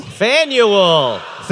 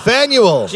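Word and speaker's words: Faneuil: Fanuel. – Fanuel.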